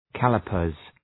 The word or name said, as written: calipers